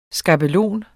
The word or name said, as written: skabelon